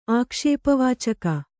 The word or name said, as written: ಆಕ್ಷೇಪವಾಚಕ